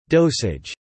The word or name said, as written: dosage